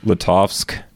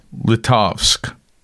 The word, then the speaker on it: Litovsk: Litovsk. – Litovsk.